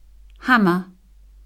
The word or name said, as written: hammer